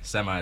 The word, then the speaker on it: Semai: Semai. Semai is my name. – Spell it for me. – S-E-M-A-I.